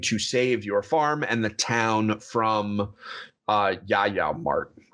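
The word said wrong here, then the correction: JojaMart: or the Yoja, the Walmart or whatever. – It's Joja Mart.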